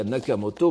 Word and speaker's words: Nakamoto: Et euh, pour le cas de Nak alors évidemment maintenant tout le monde veut prononcer Tominaga Nakamoto parce que il signe lui-même, nous allons voir dans la préface euh, de tout à l'heure, son nom Nakamoto, mais parce qu'il il se désigne lui-même.